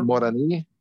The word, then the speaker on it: Moroni: It's spelled M O R O N I. – It's oh, Moroni, Mer- Moroni. – Yeah. – And we would call it Moroni. – Moroni. Yep.